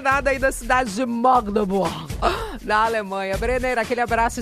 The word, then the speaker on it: Magdeburg: A pronúncia correta, é Magdeburg.